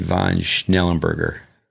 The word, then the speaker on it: von Schnellenberger: I met these two guys through David Squire and Henry von Schnellenberger. That's like a quiz. Did I say that right? – That's pretty good.